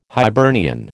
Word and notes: Hibernian: American English

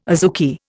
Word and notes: azuki: Indian

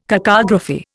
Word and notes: cacography: Indian

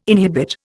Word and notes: inhibit: Indian

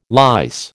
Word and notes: lice: American English

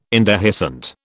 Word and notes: indehiscent: British English

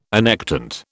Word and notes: annectent: British English

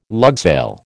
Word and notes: lugsail: American English

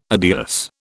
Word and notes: adios: British English

